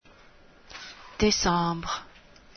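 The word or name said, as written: décembre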